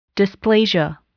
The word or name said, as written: dysplasia